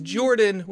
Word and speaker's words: Jordan: My name is Jordan, with a silent PH in the middle, and I am joined always by my wonderful co-host, Sir Lucian, over at Sir Lucian Gaming.